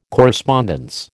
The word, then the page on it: correspondence: American English